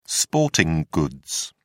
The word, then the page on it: sporting goods: British English